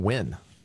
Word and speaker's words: Huynh: Because it's spelled N-G-A-N, Sung, is how you pronounce it, and then H-U-Y-N-H, win.